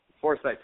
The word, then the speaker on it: Forsyth: Forsyth, how you pronounce it, Yes. – right? – Yes.